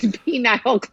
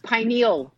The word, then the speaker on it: pineal: It's pineal gland. – Pineal, not penile, yeah. But pineal.